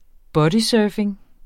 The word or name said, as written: bodysurfing